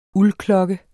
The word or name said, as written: uldklokke